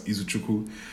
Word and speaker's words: Izuchukwu: But the long form is Izuchukwu. It's a native Nigerian name. It's pronounced as Izuchukwu.